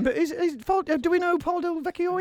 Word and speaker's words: Paul Del Vecchio: how do you pronounce this, Sol? Can you, our pronunciation geezer? Come over to the microphone. – Paul Smith. – No, he makes jeans. – Paul Del Vecchio.